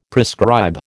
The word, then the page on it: prescribe: American English